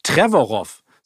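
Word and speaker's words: Trevorrow: Ich meine, das ist äh, ja auch äh, Colin Twer Twerarov, nee, wie heißt er nochmal? Colin Trevorov. So wird er ausgesprochen, ja.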